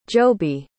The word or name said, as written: joby